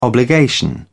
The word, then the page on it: obligation: British English